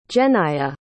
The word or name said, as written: jennaya